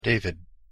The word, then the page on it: david: English